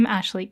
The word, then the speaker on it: Ashleigh: It's just my name, Ashleigh King.